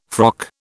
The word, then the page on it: frock: British English